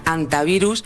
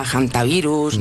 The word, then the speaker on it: hantavirus: No es infrecuente oírla quizá de ambas formas, pero lo adecuado es en efecto dejarla muda, decir antivirus y no aspirarla, hantavirus, ni nada similar.